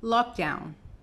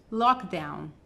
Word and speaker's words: lockdown: que seria verdadeiramente lockdown, lockdown, lockdown. Repete comigo? Lockdown, lockdown, lockdown. Então, veja que não é lock e não é down, é lockdown.